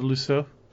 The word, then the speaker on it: Lisieux: Oh yeah, Therese of Lisieux.